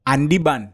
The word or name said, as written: ANDIBAN